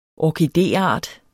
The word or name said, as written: orkideart